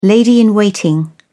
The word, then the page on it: lady-in-waiting: British English